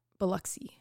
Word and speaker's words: Biloxi: Biloxi. – Biloxi. – B-I-L-O-X-I.